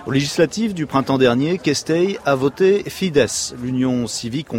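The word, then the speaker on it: Keszthely: c'est à Kestei que nous allons, j'imagine que je le prononce n'importe comment. – C'est très bien.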